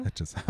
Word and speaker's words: Hedges: Und dann im Prinzip für sie die nächsten Drehtage alles nur dieser Roadtrip mit Lukas Hedges. Gott. – Lukas Hedges, ja. – Hedges. Achso, jetzt habe ich es richtig gesagt, sorry.